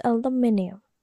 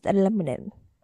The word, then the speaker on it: aluminium: Like in Indonesian, it's perfectly pronounced aluminium. But those American, this is aluminum.